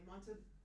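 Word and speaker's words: Montalbán: Ricardo, help me pronounce his last name properly. Montalban.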